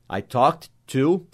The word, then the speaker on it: talked: Se quiser acrescentar ainda sobre algum assunto, about, A-B-O-U-T, about, daí fala o assunto. Se você quiser mostrar que você está usando a pronúncia certa, em vez de você colocar o talked com ed, coloque o T-A-L-K e entre colcheias, coloque a letra T. Isso mostra que você está pronunciando assim. I talked, coloca T-A-L-K, entre colcheias, T.